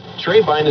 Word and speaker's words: Trebein: Trebine is spelled T-R-E-B-E-I-N.